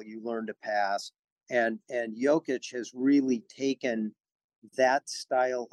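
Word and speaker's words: Jokić: Nikola Jokic. – Okay, one more time. – Nikola Jokic. – Okay, Jokic. That's even a little bit like the vowel sounds like an A-W almost more than an O.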